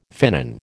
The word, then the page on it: finnan: American English